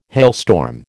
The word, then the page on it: hailstorm: American English